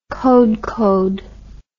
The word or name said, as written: kodkod